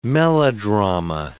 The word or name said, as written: melodrama